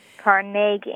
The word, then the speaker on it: Carnegie: Carnegie, accent on the second syllable. – With more of an A than an E.